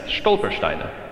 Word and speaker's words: Stolpersteine: But that these blocks are what is called Stolpersteine. Am I saying that right? Yes, Stolpersteine.